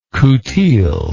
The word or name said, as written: coutil